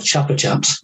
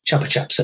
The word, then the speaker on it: Chupa Chups: Yeah, yeah, it was never pronounced Chupa Chups. That's a typical English version of a word that could sound much better.